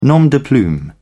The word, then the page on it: nom de plume: British English